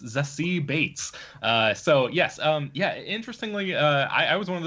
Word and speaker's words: Beetz: Yeah, so first of all, I just learned recently that this actress's name is pronounced Zazie Beetz, like as in Bates Motel.